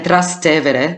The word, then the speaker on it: Trastevere: And Trastevere, because that's the way you pronounce it, because it's a bit of a tongue, tie for so many people.